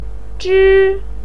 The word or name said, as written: zhi1